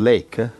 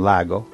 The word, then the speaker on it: Lake: John Lake, John Lago, in inglese si scrive Lake, L-A-K-E, John Lake, lo trovate in internet.